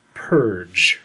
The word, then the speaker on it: PERG: The title of the book was P-E-R-G. And underneath it had a pronunciation key. Well, it just, in parentheses, said pronounced purge.